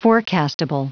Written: forecastable

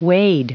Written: wade

